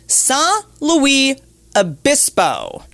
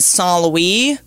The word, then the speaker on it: San Luis Obispo: And I just learned that people there just say slow. Slow, California. – How do you pronounce it to get in trouble? – Because so Russell Brand, I guess, went to San Luis Obispo once and called it San Luis. They don't like that.